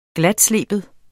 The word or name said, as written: glatsleben